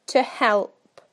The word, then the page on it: to help: British English